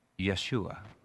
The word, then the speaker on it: Yeshua: In Hebrew, that word salvation, the feminine noun, sounds like this. Yeshua. Hopefully you'll notice that it's basically how the name Jesus is pronounced in Hebrew and the Aramaic of Jesus' day.